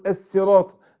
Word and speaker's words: الصراط: فقول اهدنا الصي الصي الصي، يبقى الصاد حرف صفير وبين الكسر كسره، لكن اياك وانت تنطقها سين. اقول الصراط، لا الصي، شوف حرف الصفير اهو، الصراط الطل مستقيم طل.